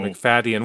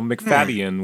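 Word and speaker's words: Macfadyen: Like, should we pronounce the Y? I just kind of, so McFaddian, which, yeah, it's kind of hard to say, but I don't, the Y is not silent.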